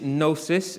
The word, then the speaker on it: gnosis: One is gnosis, it's spelt G-N-O-S-I-S.